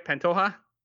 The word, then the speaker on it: Pantoja: They win 2-0 away at Atletico Pantoja. Did I say that right? Pantoja? – Sounded good. – Sounded right.